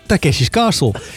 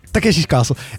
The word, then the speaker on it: Takeshi's Castle: Takeshi's Castle. Jij legt de klemtoon verkeerd. – Wat is het dan? – Takeshi Castle. – Het is Takeshi Castle. – Nee, het is niet Takeshi Castle.